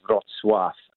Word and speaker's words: Wrocław: The spelling is Wrocław, but the pronunciation is Wrocław, in the southwest of Poland, the Tarzynski Arena, and you are fundamentally with 40,000 Ukrainian refugees.